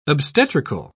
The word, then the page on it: obstetrical: British English